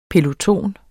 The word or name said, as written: PELOTONEN